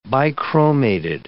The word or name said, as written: bichromated